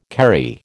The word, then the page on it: Kerry: American English